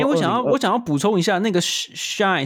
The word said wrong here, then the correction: Shein: 是不是那个要怎么念 S-H-E-I-N Shein 是不是？